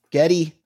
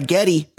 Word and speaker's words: Geddy: Geddy, real name Gary, goes by Geddy because his Hungarian grandmother, that's how she pronounced Gary.